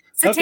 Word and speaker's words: satanic: Santanic? Is that not Santanic? Is that it? Is that how you say it? – Satanic. Okay.